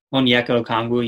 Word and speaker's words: Onyeka Okongwu: Did I pronounce that right? – Onyeka Okongwu, yeah. Close enough, okay.